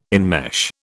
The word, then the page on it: enmesh: British English